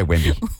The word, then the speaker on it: Wembanyama: Wembanyama. I can't say Wembanyama. – Just say Wemby.